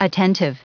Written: attentive

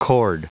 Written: chord

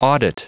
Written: audit

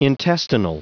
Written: intestinal

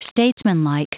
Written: statesmanlike

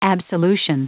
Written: absolution

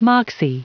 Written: moxie

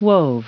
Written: wove

